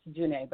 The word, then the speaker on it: Janet: I can be reached on social media at Jocelyn_Janae, and it's pronounced Janae, but it's spelled J-A-N-E-T.